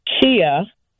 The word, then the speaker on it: ceya: And what it is that the word in the Lakota language, it's pronounced Chia, and it's spelled C-E-Y-A, is the word to cry, but it's from the root word to pray.